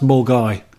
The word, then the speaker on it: Milngavie: Milngavie. – That is a place in Glasgow that every English person gets wrong, and I did the first time. It's pronounced Mulgai.